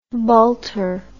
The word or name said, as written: bulter